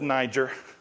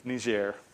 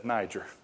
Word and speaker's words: Niger: They told me I pronounced that wrong in the first service. I said Niger. It's Niger. We said Niger, where I'm from anyway.